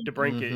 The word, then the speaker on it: DeBrincat: Hours after we discussed it, Alex DeBrincat, am I saying that correctly? – DeBrincat, mm-hmm.